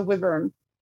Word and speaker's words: wyvern: how'd you say it? – The wyvern? – Yes, I was going to say it right. Yeah, it was the wyvern.